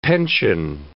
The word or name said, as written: pension